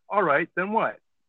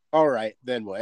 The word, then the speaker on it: All Right, Then What?: That's how it's written, but you just say it. All right. Then what? – All right. Then what?